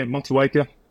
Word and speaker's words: Motueka: It's Motueka? – Yeah, Motueka.